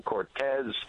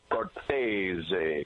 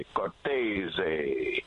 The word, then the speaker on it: Cortese: Well, like you said, old school Cortez, but I went straight to the horse's mouth one day and I said to my dad, how do we pronounce our last name? He said, Cortese, Cortese.